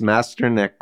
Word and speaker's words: Mestyanek: And I hope I pronounced Mestyanek correctly.